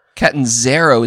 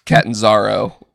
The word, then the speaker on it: Catanzaro: Catanzaro is how they were pronouncing it on TV, which was Catanzaro, which bothers me because she comes from another TV show where it's Catanzaro because it's an A, not an E.